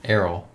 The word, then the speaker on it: Errol: Errol. – Errol? Is that different than how the name's normally pronounced? – I don't know. I think you pronounce like E-A-R-L, Earl, but it's yeah. Errol, E-R-R-O-L.